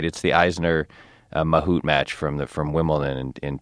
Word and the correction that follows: Isner: I think you didn't pronounce Isner right. – Isner? Isner? – You pronounced Mahut right. – I got Mahut and blew Isner.